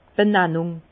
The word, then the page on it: d' Bennannùng: Bas-Rhin; Herrlisheim